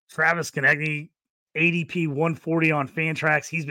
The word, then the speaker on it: Konecny: Travis Konecny did a great job there.